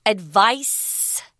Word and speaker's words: advice: If you advise someone, that's a verb form of this word, advice. Notice the difference in pronunciation. Advice.